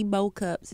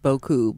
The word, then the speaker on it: beaucoup: Merci beau cups, as my dad would say. – Merci beau cups. – Beau cups. – It's pronounced beaucoup, but you know.